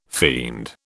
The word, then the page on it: fiend: British English